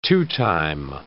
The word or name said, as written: two-time